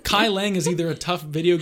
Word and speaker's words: Kai Leng: It is Kai Leng. I can spell that for you if you like.